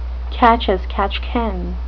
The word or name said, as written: catch-as-catch-can